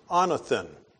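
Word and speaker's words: anothen (ἄνωθεν): The Greek word for born again is pronounced anothen.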